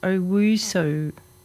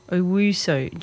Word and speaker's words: Owusu: So it's pronounced, his last name is pronounced Owusu. Owusu.